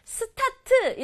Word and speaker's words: start: Start, start, start 이렇게 알고 있는 친구들 많죠. Start 세 박자로 발음하지 않고 한 박자로 start, 시작하다 또는 출발하다 이런 뜻도 되고요.